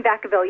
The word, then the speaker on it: Vacaville: In Vacaville, yes. – Vacaville, is that how you pronounce it? Okay.